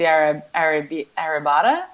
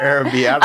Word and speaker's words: arrabbiata: He's eating delicious spicy arrabbiata. How do you say that? Arrabbiata.